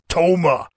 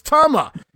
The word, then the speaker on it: Tama: Tama now in for the heels as well, and he has fun no-selling Jimmy Powers, while Jesse Ventura continues to pronounce the name, it's Toma. As Gorilla finally corrects Jesse on the pronunciation, no, Jess, it's Tama.